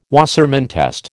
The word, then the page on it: Wassermann test: American English